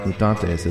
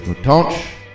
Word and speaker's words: Os Mutantes: Don't believe them when they tell you it's pronounced Mutantes, it's Mutantes.